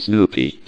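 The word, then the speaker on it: Snoopi: My first name is Snoopy, because I like the dog Snoopy.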